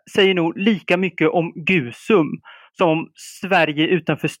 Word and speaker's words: Gusum: Gusum, med risk för att jag uttalar det helt fel, den nämndes aldrig. Är, det typiskt och är det ett sånt förbiseende som är en anledning till att du skrivit boken? – Eh, ja, det kan det på sätt och vis sägas vara och du uttalar Gusum alldeles rätt.